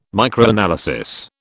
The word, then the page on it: Microanalysis: British English